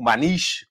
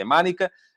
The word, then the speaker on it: Maniche: o Maniche, como se dizia em Portugal, mas a pronúncia correta é Manica, um, que uh, saiu ontem no F80.